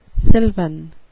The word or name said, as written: sylvan